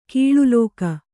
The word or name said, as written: ಕಳಲಿಕೆ